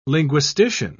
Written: linguistician

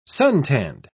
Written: suntanned

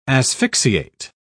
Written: asphyxiate

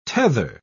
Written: tether

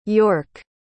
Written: york

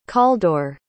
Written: calldwr

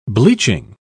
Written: bleaching

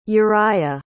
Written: uriah

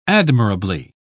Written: admirably

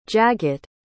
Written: jagat

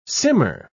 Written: simmer

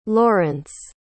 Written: laurence

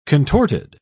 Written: contorted

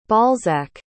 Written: balzac

